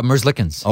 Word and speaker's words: Merzlikins: Oh, Merzlikins. – Okay, because a couple of the Columbus guys yesterday weren't pronouncing the K.